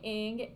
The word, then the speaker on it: Ng: So my last name, Ng, is Chinese. My dad is Chinese American. And even my pronunciation is likely very much Americanized.